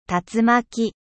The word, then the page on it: tornade: French